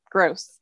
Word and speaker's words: Grose: It's Grose.